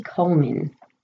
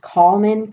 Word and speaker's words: Collman: We're going to talk about Katie Collman. I've heard it pronounced Collman because it's spelled C-O-L-L-M-A-N, but I think it's Collman. It's just spelled different.